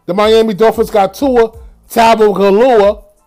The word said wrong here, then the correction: Tua Tagovailoa: Tabavalua. Who can pronounce this kid's name for me? Can anybody pronounce this kid's name? Tua Tagovailoa. Tua Tagovailoa like Rick Ross said, Tua Tagovailoa. I think that's the right pronunciation.